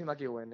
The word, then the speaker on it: McEwen: Yo he dicho Flores y McEwin, ¿sería así? – Flores y McEwin, eso es.